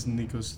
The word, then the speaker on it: Nikos: My name is Nikos Theodosiadis.